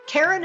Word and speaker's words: Karen: Karen is spelled the normal way, no goofy Y's or Q's, K-A-R-E-N.